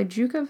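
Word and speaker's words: Dieuwke: S-O-W-A, and it's by Juca van Mulligan. You should just go to the show notes if you don't know how to spell that, because it's, D-I-E-U-W-K-E is Juca.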